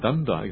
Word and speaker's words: danda: So there you are, danda, exactly. Yeah. So, how do you say it in Malay? Danda, danda. Okay, there you are.